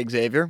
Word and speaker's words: Xavier: I say Xavier with an X.